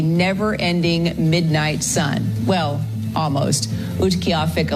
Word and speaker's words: Utqiagvik: I think they pronounce it in this clip. Utqavik. It's Utqiagvik.